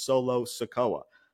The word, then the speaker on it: Solo Sikoa: LA Knight, yeah, versus Solo Sikoa.